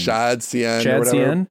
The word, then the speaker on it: shadcn: ShadCN.